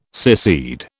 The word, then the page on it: secede: British English